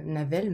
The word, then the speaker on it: Nawel: Il y a un personnage qui s'appelle Nawel, qui est euh, Navel, même si je le prononce bien, qui est un personnage relativement secondaire, qui est un garçon. Et ça s'écrit E2LE e à la fin.